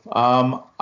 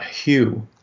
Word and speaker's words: Hugh: Um, I, am not saying I'm not saying no, my friend, but um, with the dialect that I speak, H U G H is pronounced U, not hue. Ah. And so for me, it's homophonous with Y O U and having some little,